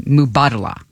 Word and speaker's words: Mubadala: I say it wrong every time. Mubadala. – There you go.